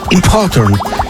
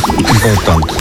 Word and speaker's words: important: Dobry akcent był, jeszcze raz powiedz, różnica między impotent a important. – Impotent. – Nie, to jest ważny, a important. To jest important.